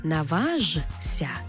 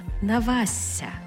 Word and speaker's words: наважся: Тобто пишемо «жся», але читаємо «зся». «Зся». Тобто не «наважся», а «навася».